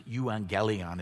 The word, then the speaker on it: euangelion: The Greek word that's translated good news is pronounced euangelion. It's where we get our word evangelism.